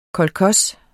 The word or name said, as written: kolkhos